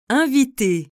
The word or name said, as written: inviter